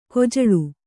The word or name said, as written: ಕಜ್ಜಾಲಿ